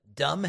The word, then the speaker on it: dumb: Then Trump gets into this whole thing of the Democrats, D-U-M, because a lot of people didn't know that dumb has a silent B there, which means Trump didn't know that.